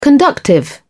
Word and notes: conductive: British English

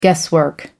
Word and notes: guesswork: American English

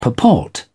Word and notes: purport: British English

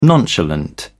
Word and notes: nonchalant: British English

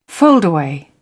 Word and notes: foldaway: British English

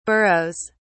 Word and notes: burrows: British English

